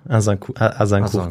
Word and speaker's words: Agincourt: Ja, Agincourt, genau.